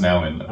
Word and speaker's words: Nouwen: In the Name of Jesus by Father Henry Nouwen, I learned over the weekend that it's pronounced Nouwen.